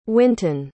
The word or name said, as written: wynton